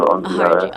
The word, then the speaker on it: Ongiara: Yeah, I say it with a hard G, so Ongiara.